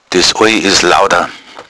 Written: lauda

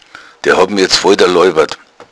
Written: daloibern

